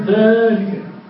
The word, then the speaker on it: ذلك: إذا قلنا الكاف هي حرف فذلك كيف تقول ذلك؟ ذلك حصلت قبلها، وأين الألف؟ ذلك سقطت في الكتابة وبقيت في النطق لكثرة الاستعمال.